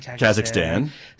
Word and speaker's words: Kazakhstan: Kazakhstan? – Kazakhstan.